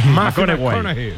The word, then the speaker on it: McConaughey: ¿Mateo McConaughey? – Sí, sí, sí, sí, sí. – Hay que ir corrigiendo la pronunciación. – McConaughey.